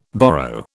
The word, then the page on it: borrow: British English